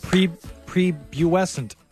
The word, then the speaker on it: prepubescent: Well, that would explain why he sounds pre-buescent. How do you say that word? – No, you got it. Prepubescent.